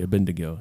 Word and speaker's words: Abednego: and Abednego. okay, Abednego.